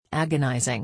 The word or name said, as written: agonising